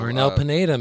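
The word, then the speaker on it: Arnel Pineda: Arnell Pineda, man.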